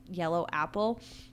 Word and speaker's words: Yeloaple: There's a plant swap, and it's at Yellow Apple, but it's spelled Y-E-L-O-A-P-L-E, but I'm pretty sure it's... Yellow Apple.